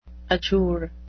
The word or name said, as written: adjure